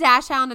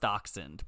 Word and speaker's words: dachshund: What's a dash hound and what's a dachshund? Are they different? – Oh, no, they're the same thing. It's pronounced dachshund, but that's the same thing.